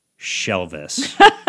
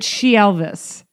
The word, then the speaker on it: Shelvis: This Elvis is actually Shelvis. – And are we saying that right, or is it She Elvis? – Well, maybe She Elvis, but I think I like Shelvis.